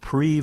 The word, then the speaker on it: Prix: Well, there's a group called Pre Versailles, and as my French speaking daughter said, it's spelled P R I X, Pre Versailles.